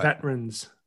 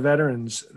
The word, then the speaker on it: veterans: Yeah, you see, this is why you're disrespecting our veterans. There's an extra syllable in there and you're trying to shorten the word.